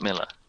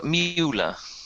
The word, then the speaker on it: Mueller: Public address system of the plane, you notice immediately, because you're from Texas, it's spelt Mueller, Mueller. Mm-hmm. But everyone in Austin would say Robert Miller. – Mm-hmm. – So just no one else would know that. But they've said the wrong thing. – Well, he said Robert Mueller, which is how you would pronounce it if you had read it and didn't know any better.